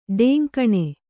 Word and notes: ಢೆಂಕಣ: kannada

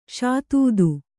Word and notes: ಷಾತೂದು: ṣātūdu

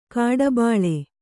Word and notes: ಕಡುಬಲಿ: kaḍubali

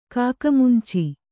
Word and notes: ಕಾಕಮುಂಚಿ: kāka munci; Kannada

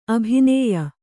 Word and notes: ಅಭಿನಯ: abhinaya; Kannada

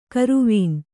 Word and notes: ಕರುವೀನ್: karuvīn

